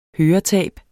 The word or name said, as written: høretab